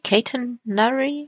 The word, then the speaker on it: Catenary: Das Wort, das ich nachschlagen musste, war Catenary. Catenary? Muss man so aussprechen.